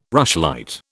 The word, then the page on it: rushlight: British English